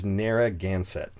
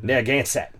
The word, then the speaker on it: Narragansett: Ah, you nailed it first try. – I had to practice a lot. – I literally looked it up on YouTube on how to pronounce it. – Oh, and if you hear like an East Coaster pronounce it, like you're adding extra stuff. Narragansett. – Narragansett. – Like I think they're taking away stuff, but adding stuff elsewhere.